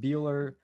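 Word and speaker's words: Buehler: Morton versus Buechler, if I pronounce that correctly. – Walker Buechler.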